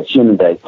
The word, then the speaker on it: Hyundai: I think Hyundai is how they say it in South Korea, Hyundai. But right. I mean, you talk to people within the team and they say Hyundai.